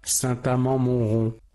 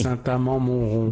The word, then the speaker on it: Saint-Amand-Montrond: Saint-Amand-Montrond. – Pues hasta ahí. – Saint-Amand-Montrond. – Venga, perfecto. Lo ha dicho dos veces. Es que yo no sé cómo se pronuncia. Yo no soy francés. – Saint-Amand-Montrond.